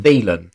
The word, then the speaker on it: Thielen: Is it Thielen or Thielen? – Thielen. Thielen. Thielen. Yeah.